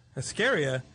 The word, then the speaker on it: Askarieh: Askarieh? – Yes. – What is that? Askarieh, how do you spell it? – A-S-K-A-R-I-E-H. – E-H. So, but that H gives it that yes. Lift at the end.